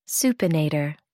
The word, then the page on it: supinator: American English